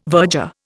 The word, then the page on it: verger: Indian